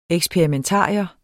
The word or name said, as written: eksperimentarium